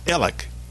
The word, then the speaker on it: Alex: It's not Alec. His name is A-L-E-X. But it's not pronounced Alec. It's pronounced Alec, like smart Alec.